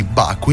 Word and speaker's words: Baku: Iniziamo con lui, che ha chiuso al quarto posto il Gran Premio di Baku in Azerbaijan. L'ho detto bene? – Hai detto benissimo.